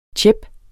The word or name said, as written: tjep